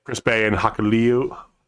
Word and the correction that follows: Hikuleo: Oh god, I can't pronounce it. Hikuleo. – Hikuleo.